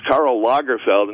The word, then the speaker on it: Karl Lagerfeld: I like the fact that the show's uber gay Fez doesn't know that the man's name is pronounced Karl Lagerfeld, and Fez calls him Karl Lagerfeld. – And then he said Field.